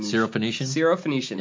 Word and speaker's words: Syrophoenician: Syrophoenician.